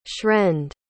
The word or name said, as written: shrend